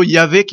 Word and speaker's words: Yahvé: Et les spécialistes historiques nous disent que c'est plutôt Yahvé qu'il faut prononcer.